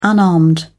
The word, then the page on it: unarmed: British English